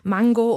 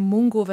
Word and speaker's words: Mungo: Es gibt wieder eine junge Hauptfigur, ein junger Mann, Mango oder eben Mungo, wenn wir es deutsch aussprechen wollen,